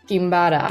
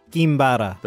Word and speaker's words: Quimbara: How do you pronounce it? Quimbara? – Kimbara. – Kimbara. You say it. – Kimbara.